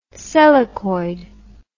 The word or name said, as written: selachoid